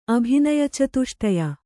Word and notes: ಅಭಿನಯಚತುಷ್ಟಯ: abhinayacatuṣṭaya

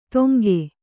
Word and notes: ತೊಂಗೆ: toŋge

